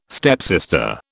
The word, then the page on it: stepsister: British English